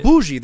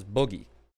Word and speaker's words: bougie: Yeah, exactly. Bougie, that's what people call that. But I just, it's one of those things where you just read it and you develop your own pronunciation. I always pronounce it as boogie.